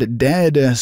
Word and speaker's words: Dehd: We've made it into August, currently 80 degrees in the Twin Cities, and that is Dog Days from the Chicago band Dehd, spell it D-E-H-D, on The Current.